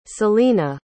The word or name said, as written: selena